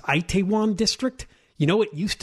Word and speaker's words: Itaewon: It's pronounced Itaewon, Itaewon. So it's a popular place for nightlife, and that became the Halloween go-to place the other night.